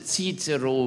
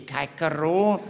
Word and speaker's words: Cicero: We correctly pronounce Cicero. We do not say Cicero.